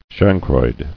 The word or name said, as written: Chancroid